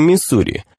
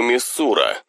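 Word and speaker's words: Missouri: Если вы родились в нашем штате, вы произносите его Миссура. Если вы родились не в нашем штате, вы произносите его Миссури. Но если вы родились в нашем штате и вынуждены прожить здесь всю жизнь, вы произносите его как мизер.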